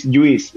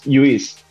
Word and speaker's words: Lluís: I was born in Catalonia, so my name is Lluís. It's in Catalan, it's L-L-U-I-S, so it's Lluís.